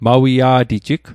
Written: mawia'tijig

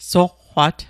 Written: soqqwat

